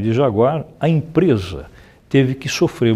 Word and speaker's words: Jaguar: Pois é, esse é o famoso Jaguar, como os ingleses gostam de pronunciar, Jaguar, ou simplesmente Jag, que é o apelido carinhoso que os britânicos dão ao produto nacional deles.